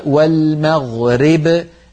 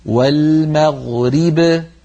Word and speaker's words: والمغرب: من جملة ذلك في الآية الأولى لما قرأنا قبل المشرق والمغرب، هذه لما نقف عليها كثير من إخواننا لما يقفون يقولون والمغرب ري، فبدلا من أن يكسروا الراء كسرا كاملا ينطقونها راء ممالة ري والمغرب، هذا لا يصح، علينا أن نقول والمغرب ريب بكسر الراء كسرا كاملا.